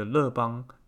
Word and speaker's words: 樂邦: 那我觉得乐邦它更接近原始的法文的这个发音。